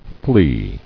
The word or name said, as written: flea